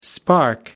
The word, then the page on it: spark: American English